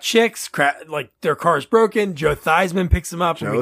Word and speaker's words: Theismann: Theismann for Heisman. Fun fact, his last name's Theismann. He changed the way they say his name just to rhyme for Heisman.